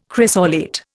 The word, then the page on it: chrysolite: Indian